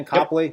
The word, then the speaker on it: Copley: Did I pronounce that right, Thane? Copley, yep.